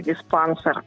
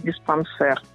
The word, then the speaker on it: диспансер: Очень распространенная ошибка – диспансер, а нужно говорить диспансер.